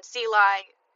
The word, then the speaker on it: Celi: Thankfully, El Rico, our producer, said, I'm not sure if I pronounced that last name correctly. Caitlin Sely, C-E-L-I.